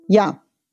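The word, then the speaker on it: ja (я): Ja. Ja heißt ich. Ausgesprochen ja. Ich heißt auf Russisch ja, ein Buchstabe nur.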